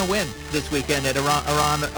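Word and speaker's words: Aronimink: You mean Aramink? Aramink. I believe I learned how to say it correctly.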